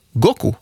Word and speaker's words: Goku: Dagen lär vara utvald för eller på grund av att på japanska kan siffrorna 5 och 9 uttalas go och ku. Goku alltså. Femman står för månaden maj och nian för datumet förstås.